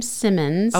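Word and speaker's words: Symons: It's S-Y-M-O-N-S. So it's actually, he pronounces his last name Simmons.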